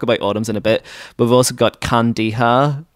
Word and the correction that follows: Kandahar: Am I saying that right? – Kandahar. – Kandahar, yeah, which is Kandahar.